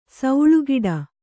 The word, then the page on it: ಸೌಳುಗಿಡ: kannada